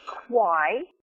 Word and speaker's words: Quy: It's LaRae Quai, and it's spelled L-A, capital R-A-E, and the last name is Q-U-Y.